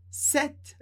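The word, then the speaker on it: sept: Tu, le, os, jour, dias, a, em português, as, set, o número set, a gente não pronuncia o P, mas pronuncia o T. Palavras curtinhas geralmente não seguem as regras gerais de pronúncia. Então, set, com esse som de T bem seco no final.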